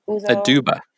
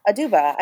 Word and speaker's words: Uzo Aduba: Uzo Aduba, how am I messing up her name? – Uzo Aduba. – Aduba. I said it right the first time.